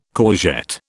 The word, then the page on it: courgette: British English